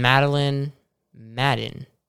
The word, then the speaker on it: Madeline Madden: And Madeline Madden. Did I say that right? – Yes.